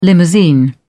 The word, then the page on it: limousine: British English